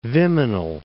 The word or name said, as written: Viminal